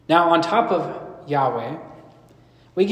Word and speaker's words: Yahweh: So when you see that, Lord, all capitals, think it's Yahweh. That's his name. And I also want to mention that you may have heard it pronounced Jehovah or Yehovah. And those are just English transliterations of this Hebrew.